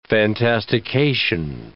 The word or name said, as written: fantastication